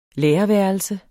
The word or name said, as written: lærerværelse